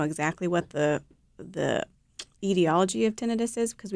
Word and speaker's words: tinnitus: We say tinnitus only because itis suggests that there's an inflammation of something.